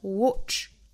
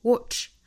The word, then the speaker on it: watch: 我们注意第一个元音 watch, watch. 元音是单元音的 o 的发音。我们在发 o 的时候呢，下巴往下移，我们可以想象口腔里面有一个乒乓球的感觉。 o o watch watch.